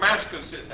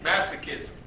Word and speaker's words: masochism: Demon possession is marked by sadism or masochism. How do you say that word? – Masochism. – Thank you. Masochism.